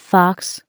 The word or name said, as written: fox